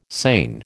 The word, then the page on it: seine: American English